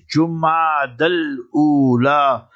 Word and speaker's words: Jumad al-Ula: O Ummah, O esteemed listeners of Radio Al-Ansar International and Markaz Sahaba, the voice of Ahlul Sunnah wal-Jama'ah, learn to write down correctly and pronounce the words properly. And it is Jumad al-Ula.